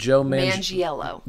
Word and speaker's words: Manganiello: Manganiello. – Joe Mangiello.